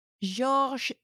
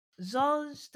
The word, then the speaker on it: Georges: And George ends with an S. Is that silent? – George. Yeah, because you pronounce the je. But not the S. Georges de Saint-Pierre. – Georges de Saint-Pierre.